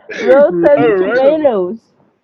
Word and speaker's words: Rotten Tomatoes: Rotten Tomatoes.